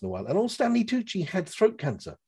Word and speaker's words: Stanley Tucci: As he says himself, and it rhymes with Gucci, Stanley Tucci, I'm Italian on both sides.